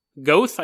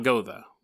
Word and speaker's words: Goethe: Goethe, I think, is how you pronounce it. It's spelt Gotha, but I'm pretty sure it's not Gotha.